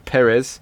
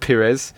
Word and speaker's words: Perez: Perez, not Pires. Perez.